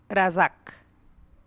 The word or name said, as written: ratxgalow